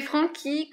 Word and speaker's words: Franchi: Donc c'est Francky comme euh, du Chianti, par exemple.